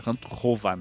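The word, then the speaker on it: Hantuchová: que eu entrevistei a Daniela Hantukova, na verdade pronuncia Hantukova, né? Mas tudo bem.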